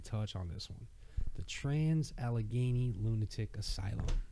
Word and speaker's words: Trans-Allegheny: Trans-Allegheny.